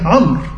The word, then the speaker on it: عمرو: فطبعا هي تنطق ايه عمرو عمرو وتزاد الواو دي بيسموها ايه زياده وهي لا تنطق في الوصل برضه. ما هو قال لك عمرو ابن زيد في الوصل برضه لا تنطق حتى في الوصل ايه لا تنطق.